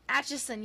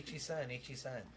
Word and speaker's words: Atchison: Atchison, yes. I can't talk. – Atchison. Just say Itchison.